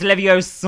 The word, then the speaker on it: Leviosa: Oh, sorry, how do you pronounce it? – It's Leviosa. – Oh, thank you.